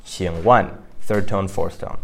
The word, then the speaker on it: 请问: And so that would be 请问. So that's pronounced third tone, fourth tone, 请问.